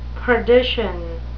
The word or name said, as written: perdition